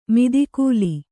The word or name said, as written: ಮದಕಲ